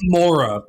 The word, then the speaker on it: Moira: Ultimately, it's Mora, but I feel like I heard them say Moira a couple times. – In the credits, it's spelled M-O-I-R-A, Moira.